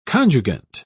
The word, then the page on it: conjugant: British English